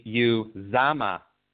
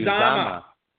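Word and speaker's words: Uzomah: That's going to be Uzama. CJ Uzama. CJ Uzama. Okay, perfect.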